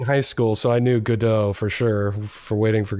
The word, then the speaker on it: Godot: Godot. I just use the, you know, like waiting for Godot pronunciation as per like the classical work or whatever.